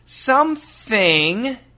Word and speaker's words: something: And what's interesting is something actually sounds like S-U-M-P, but don't finish the P. Some, go to the P, and from the P sound, just open the mouth and make an N sound.